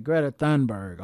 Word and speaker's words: Greta Thunberg: Yeah, Greta Thunberg. Okay, that's how you pronounce it.